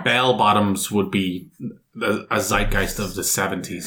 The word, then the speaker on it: zeitgeist: The zeitgeist, German pronunciation zeitgeist, is a concept from 18th to 19th century German philosophy, meaning spirit of the age or spirit of the times.